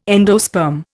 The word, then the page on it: endosperm: Indian